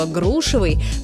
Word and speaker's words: грушевый: Но вернемся к грушам и запомним, что ударение во всех формах прилагательного «грушевый» по падает на первый слог, как в слове «груша».